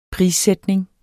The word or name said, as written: prissætning